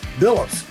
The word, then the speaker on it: Bilous: I'm talking with Keith Bilous.